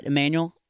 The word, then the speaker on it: Emmanuel: But we got an email from some kid named Emmanuel, and I hope I'm saying it right. Emmanuel, it's supposed to sound like Samuel.